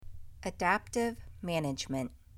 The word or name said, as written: Adaptive management